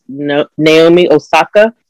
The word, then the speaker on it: Naomi Osaka: Naomi Osaka, who is a, I think I'm saying it right.